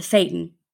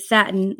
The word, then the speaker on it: Satan: Jesse was referring to Satan, but never having read the unfamiliar name before, he was mispronouncing it Satin like the fabric.